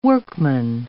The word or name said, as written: workman